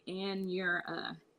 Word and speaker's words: Anura: So it's like Anura.